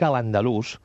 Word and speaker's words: l'Andalús: Dic la paraula, dic a l'Andalús, i ho faig en una paraula aguda, perquè era la pronúncia dels andalusins.